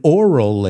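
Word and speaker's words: aural: If it's a visual experience and an oral experience. Aunque se pronuncia igual que oral.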